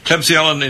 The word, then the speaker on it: Klebsiella: Yeah, it's Klebsiella, you know, you got it precise.